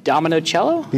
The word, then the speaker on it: Dominocielo: And here with us is, Gabe Dominocello. – You said it right.